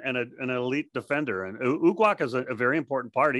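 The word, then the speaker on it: Uguak: How do you say his last name? – Ugok. – Ugok. – Ugok. – Yeah. – Ugok, yes.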